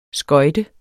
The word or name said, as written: skøjte